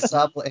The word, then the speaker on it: Sobble: It's going to include three new starting Pokemon, which are going to be Scorbunny, Sobble, and Grookey. – Are we pronouncing those right? – Yes, you are so far. – Okay, Scorbunny, Sobble, and Grookey.